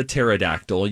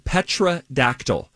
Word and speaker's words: pterodactyl: She said, I have been pronouncing pterodactyl petrodactyl, which is, if you look at how pterodactyl is spelled, which is there's a silent P in front of it.